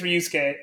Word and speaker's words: Ryusuke: Like, did you see when Ryuichi? Is it Ryuichi? I think it's Ryusuke. Let me check the director of Drive My Car just so I don't get his name wrong. I think it's Ryusuke Hamaguchi.